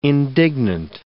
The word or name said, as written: indignant